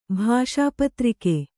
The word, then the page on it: ಭಾಷಾಪತ್ರಿಕೆ: Kannada